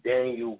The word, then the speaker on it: Danyel: My name is Daniel Green, and it's spelled D-A-N-Y-E-L oRiGiNaL, green like the color.